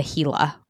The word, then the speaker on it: Gila: Oops, I made a mistake. I was supposed to call you this morning and tell you that is not how it's pronounced. It's the Gila Wilderness.